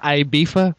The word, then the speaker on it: Ibiza: Ibiza? – Ibiza? – It's pronounced Ibiza.